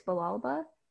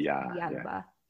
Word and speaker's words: Villalba: In English, it's Villalba, but in Spanish, the two L's make a Y sound. So it's Villalba.